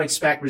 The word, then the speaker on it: expect: Cuidado com a pronúncia do T, não é expect. E sim, expect. É um T super sutil, tá? Tem que se livrar dessa mania de pronúncia que muita gente tem, que é colocar esse T no final de palavras com T. Não é expect. E sim, expect. É muito sutil. Quase não dá pra ouvir.